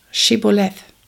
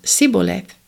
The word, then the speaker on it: Shibboleth: If he said no, then they would say to him, Say now, Shibboleth. And he said, Sibboleth, since he could not pronounce it right.